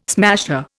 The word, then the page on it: smasher: Indian